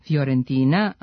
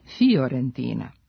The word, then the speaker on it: Fiorentina: Ovo pravilo važi i za predvokalsko fi, koje se čita i piše kao fj, Fiorentina, a ne Fiorentina.